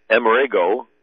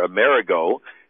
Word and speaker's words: Amerigo: And the Italian pronunciation of Emmerich is Amerigo or Amerigo,